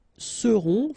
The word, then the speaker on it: seront: Ils seront, final T not pronounced, and then you've got this nasal, on, seront, elles seront.